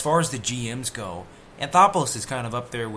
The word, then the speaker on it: Anthopoulos: It's Alex Anthopoulos, right? Or Antonopoulos? – Yep. Anthopoulos. Anthopoulos, yeah.